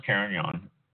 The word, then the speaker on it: Carignan: Carignan, and it's pronounced Carignan, spelled C-A-R-I-G-N-A-N.